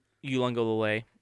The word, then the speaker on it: Uiagalelei: But I just still look across the sideline and see DJ Uiagalelei is in his Dr. Great pronunciation.